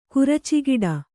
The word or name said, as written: ಕುರಚಿಗಿಡ